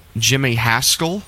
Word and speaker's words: Jimmy Haskell: I've failed to mention the orchestrations before, but this track was arranged by Jimmy Haskell. Is that how you pronounce that, Barry? – Yes.